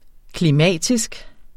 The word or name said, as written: klimatisk